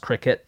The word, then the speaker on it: Kilikiti: It's actually pronounced cricket, but it's spelled Kilikiti.